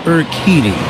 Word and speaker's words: Urquidy: Pronounced Urquidy.